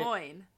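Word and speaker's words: Glóin: Because I have always said Gloin. – Like, no. – rhymes with groin. – Okay, but it has the accent mark on the O, which means the O is its own separate emphasis.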